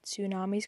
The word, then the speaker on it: tsunamis: I always find it really hard to, like, read tsunamis, because it's, like, got a T at the beginning, and, like, the T is silent, if that makes sense.